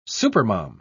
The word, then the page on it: supermom: British English